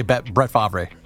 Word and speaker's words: Favre: That is how you say Brett Favre.